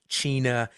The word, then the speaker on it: CHINA: Now, Sydney, the Alaska child protection law is known as Child in Need of Aid. It's pronounced CHINA.